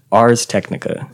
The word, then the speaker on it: Ars Technica: Ars Technica.